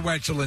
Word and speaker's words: Wetzel: I thought that Wetzel's Pretzels was just a silly name because Wetzel rhymes with pretzel.